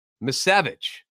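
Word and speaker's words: Misiewicz: Miskevich. That's how you say it.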